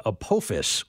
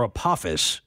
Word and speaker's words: Apophis: In 2029, an asteroid called Apophis or Apophis is Spell it. – A-P-O-P-H-I-S. – P-H-I-S.